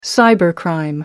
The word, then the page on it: cybercrime: American English